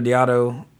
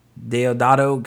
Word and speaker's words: Deodato: Uh, but anyway, uh, the director Diado Deodato,